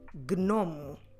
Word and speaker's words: gnomo: gnomo.